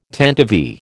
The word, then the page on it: tantivy: American English